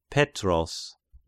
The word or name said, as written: Petros